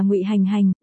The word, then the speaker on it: Ngụy Hành Hành: Mình là Ngụy Hành Hành, đọc là hành trong hành tẩu, không phải hàng trong một hàng hai hàng đâu.